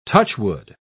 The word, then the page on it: touchwood: British English